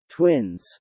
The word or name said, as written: twins